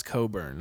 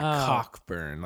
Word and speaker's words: Cockburn: It's pronounced Coburn, not Cockburn, like okay.